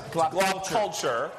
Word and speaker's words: GLoP Culture: It's Glop Culture. So it rhymes with pop culture.